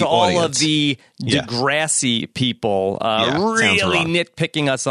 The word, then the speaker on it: Degrassi: I think it's Degrassi. – Degrassi. – Like grass.